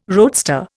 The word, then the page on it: roadster: Indian